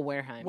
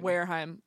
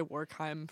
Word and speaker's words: Wareheim: Oh, Wareheim. – Wareheim. I was gonna say Warkheim for some reason.